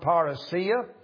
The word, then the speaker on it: parousia: It is the word parousia.